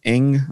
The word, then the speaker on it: Ng: Kim Ng, I believe is how you say her last name. It's just NG. I believe it said Ng.